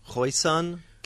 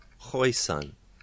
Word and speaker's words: Khoisan: Khoisan, Khoisan languages. There are African languages like Xhosa that have... – It's not Khoisan, like... – Yeah. Maybe Khois, but it's Khoisan, not Khoisa. Huh. Yeah, Khoisan.